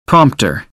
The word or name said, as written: prompt